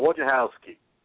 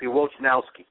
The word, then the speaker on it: Wojnarowski: From the Detroit News, the big fellow, Wojnarowski. – Yeah. – Actually, not Wojnarowski, Wojnarowski. I mispronounced the big fellow's name wrong. Wojnarowski.